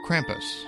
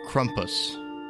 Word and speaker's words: Krampus: You may have heard him called Krampus or Krampus or Krumpus. The pronunciation of his name varies nearly as much as his depictions.